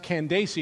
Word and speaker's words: Candace: Apparently, that word that we pronounce Candace, frankly, we Americanize it, one should be pronounced Candace. I'm going to sing this song the way the author intended it, but if we're going to be real, it's actually pronounced Candace, and it's a word that indicates, it's like a title for the Queen of Ethiopia.